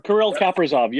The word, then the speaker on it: Kirill Kaprizov: Well, and I've also heard some rumblings about a tournament come September for, like, AHL teams and players like Alex Romanoff, Kirill Kaprizov? Am I saying that right? – Kirill Kaprizov, yes.